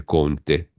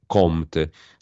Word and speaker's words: Comte: attenzione con la M, non con la N, eh, perché sennò poi si legge Conte, Comte, Auguste Comte era un filosofo francese, mi sembra uno dei razionalisti, dei positivisti, no?